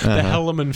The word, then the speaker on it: Helaman: Yeah, I heard it on the radio pronounced by someone who's not from here, the Helaman Fields, and I was like, it's Helaman, thank you, out-of-towner.